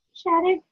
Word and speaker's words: Shaddock: Shaddock, yeah.